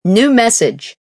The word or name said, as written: New Message